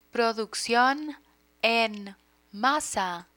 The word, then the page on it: produccion en masa: Spanish